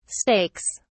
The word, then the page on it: stakes: British English